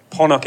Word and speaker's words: Ponoc: Actually, we should probably say up front, Ponoc is how the Japanese pronounce the word. It's a Croatian word that is pronounced Ponoc, which means midnight.